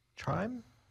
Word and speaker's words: Trym: Time? It's spelled T-R-Y-M, but I'm going to say time.